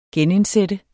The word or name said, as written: genindsætte